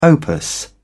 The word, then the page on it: opus: British English